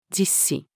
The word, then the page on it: 実施: female